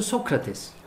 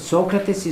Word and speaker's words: Socrates: One time in an interview he said, Socrates is not the right pronunciation. The right pronunciation is Socrates.